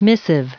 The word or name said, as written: missive